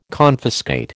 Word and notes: confiscate: American English

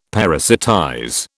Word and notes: parasitize: British English

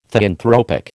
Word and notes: theanthropic: American English

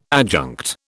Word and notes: adjunct: British English

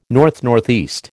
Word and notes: NNE: American English

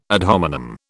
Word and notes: ad hominem: British English

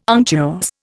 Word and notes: unctuous: Indian

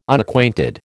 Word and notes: Unacquainted: American English

